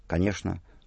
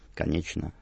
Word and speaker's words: конечно: Традиционное московское произношение «шн» в словах «конечно», «булочное», и традиционное петербургское «чн» – «конечно», «булочное», «молочное» и так далее.